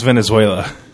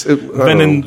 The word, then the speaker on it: Venezuela: I like how you always mispronounce Venezuela. – Yeah. It's Venin.